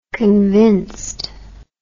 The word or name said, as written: convinced